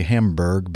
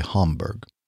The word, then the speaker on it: Hamburg: They uh, said basically, well, you Americans say Hamburg, but uh, we Germans uh, in our mother tongue say Hamburg. More of a ha ah.